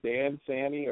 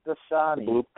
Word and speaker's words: Dasani: Because I have to admit that Dan Sani, how do you say it? – Dasani.